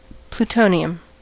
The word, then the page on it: plutonium: American English